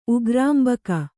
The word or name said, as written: ಉಗ್ರಾಂಬಕ